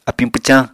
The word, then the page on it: apiñ pwcañ: Apatani